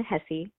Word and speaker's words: Hesse: I'm Jen Hesse, like Jesse, but with an H, calling from Everett.